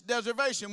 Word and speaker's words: deservation: It's deservation. What is deservation? Rhymes with reservation.